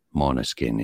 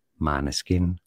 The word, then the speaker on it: Måneskin: It is said as Monoskin in Danish, the original Danish pronunciation. Now in Italian, since it's an Italian band, it's fair enough to pronounce it the Italian way. They say it either as Maneskin or Maneskin.